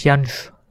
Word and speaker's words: Jansz: J-A-N-S-Z wird es ausgesprochen. Wahrscheinlich Jansch. Jansch. Äh,